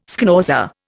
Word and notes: schnauzer: Indian

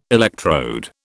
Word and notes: electrode: British English

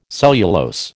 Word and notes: cellulose: American English

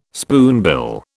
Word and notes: spoonbill: British English

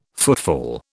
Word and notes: footfall: British English

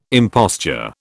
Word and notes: imposture: British English

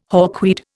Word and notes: hawkweed: Indian

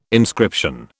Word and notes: inscription: British English

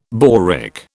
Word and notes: boric: British English

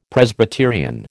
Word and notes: Presbyterian: American English

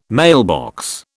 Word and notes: mailbox: British English

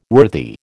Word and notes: worthy: American English

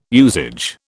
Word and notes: usage: British English

